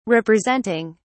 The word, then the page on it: representing: British English